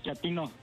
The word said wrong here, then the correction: chatino: ¿Chapino? – No, chatino. – Es como chato, ¿verdad? Chatino. – Sí, azunero, como chato, chatino.